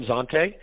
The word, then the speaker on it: Zante: It's Fresh Foam, and I don't know how to pronounce that last word, Zante. – Yeah, it's the Fresh Foam Zante.